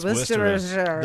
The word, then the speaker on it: Worcestershire: How to pronounce Chateau Rosie mentioned Worcestershire. – What do you always say about the traffic? It's Worcestershire. That is Worcestershire. – This is Worcestershire sauce.